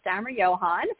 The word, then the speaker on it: Stammerjohann: It's Lisa Stammerjohann.